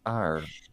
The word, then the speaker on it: Eyre: which is Steampunk Jane Eyre.